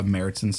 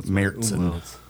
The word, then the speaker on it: Märzen: Most of the Oktoberfest that you'll find like will be a Märzen style here. – Yeah, it's spelled Marzen, but with there's an umlaut above the A, so it flattens it to Märzen.